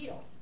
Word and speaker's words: Giehl: G-I-E-H-L. It's Gill. Gill.